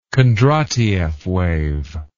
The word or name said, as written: Kondratieff wave